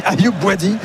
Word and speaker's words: Ayoub Bouadi: Je me disais c'est qui Ayoubi, je regardais ma fiche, je me dis c'est ça, j'avais besoin d'un coup de brumisateur. – Il manquait une syllabe au milieu, quoi.